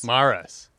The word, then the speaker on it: Maras: I just say Maras. – Maras. Okay. – Yeah, but I mean, you're really supposed to roll the R, but I don't.